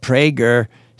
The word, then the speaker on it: Prager: Right-wing radio show host Dennis Prager, pronounced Prager, said he has been actively trying to get a coronavirus infection all along.